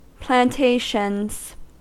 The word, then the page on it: plantations: American English